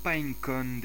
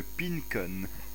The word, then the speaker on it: Pinecone: Le pinecone d'Everwood. Hein, tiens, je vais le prononcer à la française, ça sera marrant. Le pincone.